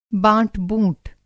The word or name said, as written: बाँट-बूँट